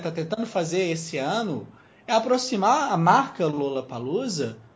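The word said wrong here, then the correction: Lollapalooza: né, Lala Palusa, na né, pronúncia correta, é, do que é o Rock in Rio.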